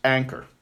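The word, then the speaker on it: anchor (ἄγκυρα): The Greek word for anchor is anchor, spelled, well, changing it to English letters, A-G-K-Y-R, but pronounced anchor. Because the G, when it comes before a certain letter, changes to an N sound in Greek. So it's anchor, A-G-K-Y-R.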